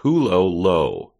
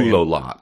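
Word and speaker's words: Hulohot: It's pronounced Hulolo. – Yeah, I mean, I would just say Hulolot.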